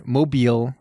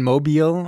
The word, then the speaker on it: Mobile: And we're in Mobile, right? Mobile? – Yeah, Mobile, Alabama. – I didn't want to say it wrong. I didn't want to say it like mobile, like an idiot. It's Mobile, Alabama.